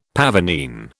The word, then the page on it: pavonine: British English